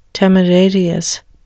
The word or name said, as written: temerarious